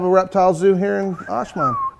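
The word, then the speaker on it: Ajman: And I was, Walter is actually working on a project in Ashman. – Yes. – If I said that correctly. – Ashman, yeah, you got it.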